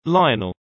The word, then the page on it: lionel: British English